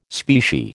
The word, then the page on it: specie: American English